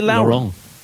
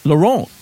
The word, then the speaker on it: Laurent: You'll be back for Lauren. How do you pronounce his name? Is it Lauren? – Laurent. – You're saying Laurent. Mm. I thought it was Lauren.